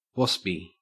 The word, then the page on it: waspy: Australian English